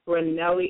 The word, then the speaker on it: Ranelli: Am I pronouncing your first name right? Ranelli? – Ranelli is perfect.